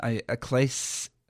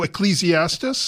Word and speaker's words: Ecclesiastes: I'm going to pronounce this moron, Ecclesiastes? – Oh, Ecclesiastes? – Thank you.